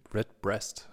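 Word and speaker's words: Redbreast: Und äh, mit äh, der Red, Bre Red Breast hast du mir gerade eben. Ich hätte das jetzt so ausgesprochen, ja. ja. – Wie die Breast. Breast, okay. Ja.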